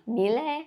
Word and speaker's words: Milei: Milei.